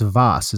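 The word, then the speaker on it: Waas: It's W-A-A-S, but it's pronounced Voss. Is that right? – So, yeah.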